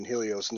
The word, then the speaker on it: Helio: Helio. I was there. – Helio, Helio. Sorry, I pronounced the H.